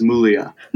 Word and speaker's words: Muglia: My family says Mulia. – Mulia. – Where the G is silent.